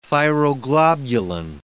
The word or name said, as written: thyroglobulin